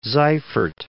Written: Seifert

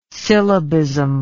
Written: syllabism

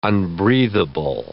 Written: unbreathable

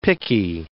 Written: picky

